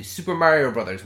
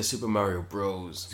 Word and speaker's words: Super Mario Bros.: So we're talking about the Super Mario Brothers movie. Uh, I think it's pronounced the Super Mario Bros. – Super Mario mm. Bros. – Yeah, Bros. Mm-hmm.